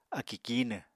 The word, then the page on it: akì kíinw: apatani